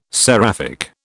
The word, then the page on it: seraphic: British English